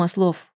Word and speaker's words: Ослов: Да он сам Ослов. Данис Ослов. С ударением на второе «о».